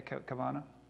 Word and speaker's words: Kavanaugh: You think about what happened recently with, I don't know how to say his last name, Kavanaugh? Okay, Kavanaugh.